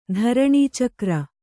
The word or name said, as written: ಧರಣೀಚಕ್ರ